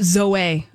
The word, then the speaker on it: Zoe: It's pronounced Zoe, according to their YouTube page. – Zoe. – With no umlauts.